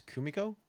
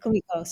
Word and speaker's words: Kumiko: And then you go to, I don't want to butcher the name, Komiko. Am I saying it correctly? – I think it's Kumiko, right? – Kumiko, sorry.